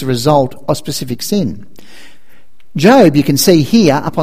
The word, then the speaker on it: Job: The book of Job, you can pronounce it Job, but it's actually Job, says, it's a whole book of the Bible, it's a long book in the Old Testament.